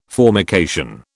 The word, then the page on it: formication: British English